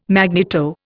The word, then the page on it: magneto: Indian